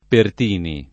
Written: Pertini

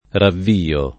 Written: ravviare

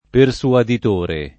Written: persuaditore

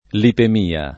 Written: lipemia

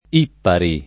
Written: Ippari

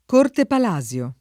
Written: Corte Palasio

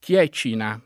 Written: Chiecina

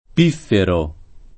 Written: pifferare

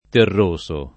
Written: terroso

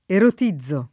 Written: erotizzare

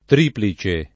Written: triplice

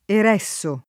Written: Ereso

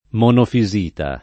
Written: monofisita

